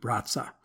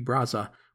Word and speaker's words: Brazza: meaning it's pronounced more like Brazza, and I'll try to say that when referring to his family. However, Brazza would later become a French citizen, and the pronunciation I found tends to be Brazza, which is what I will use when talking about our star.